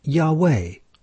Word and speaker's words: Yahweh: When vowels are added, it is pronounced as Yahweh, or Yehawah.